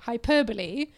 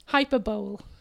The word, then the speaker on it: hyperbole: I did an entire presentation about hyperbole, and I pronounced it hyperbole. – Yes! – Yeah. – Oh, my God. – It's hyperbole.